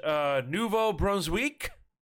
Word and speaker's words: Nouveau-Brunswick: There's a league in, how do we say this in French? Nouveau-Brunswick? – Yes.